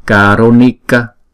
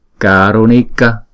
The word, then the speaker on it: Karunika: Chữ A này chúng ta đọc là A dài, và chữ N này chúng ta để cong lưỡi lại và búng ra Ni. Karunika. Karunika.